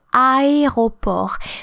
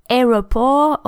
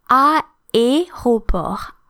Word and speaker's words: aéroport: So I know that's difficult for English speakers who tend to say aéroport, or because we have two vowels and they don't actually blend, you can't combine the A and the E with the acute to form a new sound. So these two vowels need to be pronounced separately.